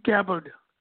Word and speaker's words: Gabbard: Gabbard.